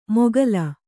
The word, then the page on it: ಮೊಘಲ್: Kannada